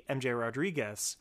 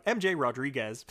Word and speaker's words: MJ Rodriguez: And particularly MJ Rodriguez, who I've just right this second completely said their name wrong. MJ Rodriguez.